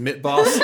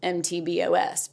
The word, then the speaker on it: MTBoS: Last year, you know, I just thought it was MTBOS, but you have to actually sound like a boy band.